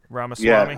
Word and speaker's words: Ramaswamy: Um, I thought that um, how do you pronounce his name? The guy, the Indian guy, Vivek uh, Ramaswamy.